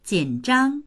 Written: jin3zhang1

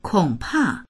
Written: kong3pa4

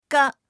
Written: ge

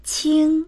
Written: qing1

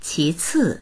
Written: qi2ci4

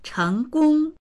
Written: cheng2gong1

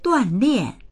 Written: duan4lian4

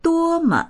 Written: duo1me

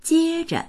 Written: jie1zhe

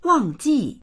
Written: wang4ji4

